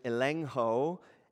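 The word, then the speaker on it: elengho (ἐλέγχω): Now, the word convict here, it's pronounced in the original language, elengho, and it's the idea of exposing.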